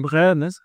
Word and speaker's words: De Bruyne: De Bruyne hat er auch oft gesagt, immer wenn er wohin gewechselt hat, bei der neuen Station.